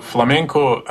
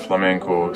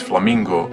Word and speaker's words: flamenco: Now, as far as the common mispronunciation of flamenco, some people say it's flamingo music, but it's really, there is no relation to the bird.